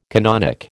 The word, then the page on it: canonic: American English